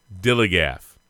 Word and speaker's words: DILLIGAF: And I can relate to their attitude, too, in a word, dilly-gap. That's spelled D-I-L-L-I-G-A-F. – Yeah, if you need some help, look, Georgia fans, I'm just saying. D-I-L-L-I-G-A-F, dilly-gaff.